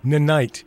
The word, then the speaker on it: Ninite: It's called Nynite. NINITE. – Right. Well, it's Nynite. I always pronounced it Nynite. – Nynite.